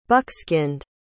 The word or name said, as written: buckskinned